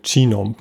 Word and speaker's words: Genome: Ähm, du beschreibst im Buch das Human Genome Project, oder wie spricht man es aus? Genome? – Human Genome Project.